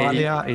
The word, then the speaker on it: Balea: but Calin is spelled C-A-L-I-N. And your last name is spelled B A. – Balea, it's B as in burrito, A L E A.